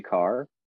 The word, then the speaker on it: Kaur: And, you know, oddly enough, like the inst- the Grand Poets, Rupi Kapoor. What what? Uh, I don't. Did I pronounce that right? I don't. Ru- I think it's Rupi Rupi Kaur. A oh. A U R. – Oh, Kaur. Kaur.